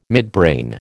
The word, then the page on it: midbrain: American English